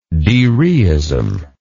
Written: dereistically

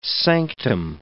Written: sanctum

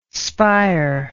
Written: spire

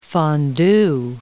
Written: fondu

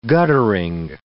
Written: guttering

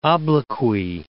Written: obloquy

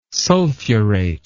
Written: sulfurate

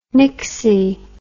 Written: nixy

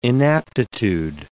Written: inaptitude

